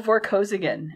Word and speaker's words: Vorkosigan: And our other protagonist shows up, and that is Errol Vorkosigan. Am I pronouncing that right? – Yes.